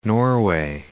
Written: Norway

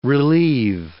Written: relieve

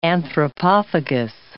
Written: anthropophagous

